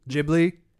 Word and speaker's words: Ghibli: Ghibli. – It's a J. It's a soft G, not a hard G.